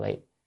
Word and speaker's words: vị: Chữ này đọc là vi, vi đọc là vị, còn chữ cố là nên, bởi vì có thể làm chướng ngại đối với sự tu đạo.